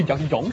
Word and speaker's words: Garkitang: Oui, Garkitang, ça prononce comme ça.